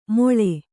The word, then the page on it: ಮೊಳೆ: kannada